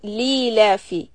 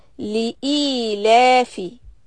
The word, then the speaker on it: لِإِيلَافِ: You know, the wrong pronunciation is when people say li-lafi. It's not li-lafi, it's li-e-lafi.